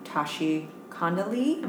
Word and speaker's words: Tashi Condelee: Are incredibly, incredibly lucky to have the one, the only Tashi Condelee.